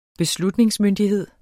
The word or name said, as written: beslutningsmyndighed